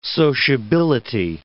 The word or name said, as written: sociability